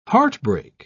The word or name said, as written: heartbreak